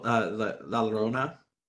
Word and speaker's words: La Llorona: But for the most part, you know, you do see her today as like, I think one of the culturally the Yoyorina. Did I say that correctly? I did not say that correctly, did I? The weeping woman, the crying woman, the vengeful ghost from Mexico. – Oh, La Llorona? – Yeah, Llorona.